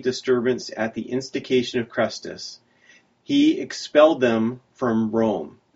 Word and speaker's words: Chrestus: But what Suetonius says here is that the Jews were causing a disturbance because of the instigation of Crestus, and that's spelled C-H-R-E-S-T-U-S.